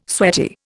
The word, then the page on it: sweaty: Indian